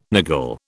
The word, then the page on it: niggle: British English